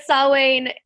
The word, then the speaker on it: Samhain: you would think it would be pronounced Samhain or Samhain, because it's S-A-M-H-A-I-N, but because it's Celtic or Gaelic, it's pronounced Samhain.